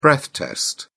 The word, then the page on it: breath test: British English